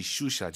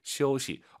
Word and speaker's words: 休息: IU 사이에 역시 오 넣어서 시 어우예요. – 休息. – 그렇죠. 시우시 슈시 하지 마시고요. 시우시.